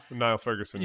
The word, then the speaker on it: Niall Ferguson: Niles Ferguson, is that how you say his name? – Niall Ferguson.